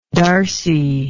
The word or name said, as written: darcy